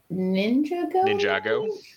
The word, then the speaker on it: Ninjago: Ninjago.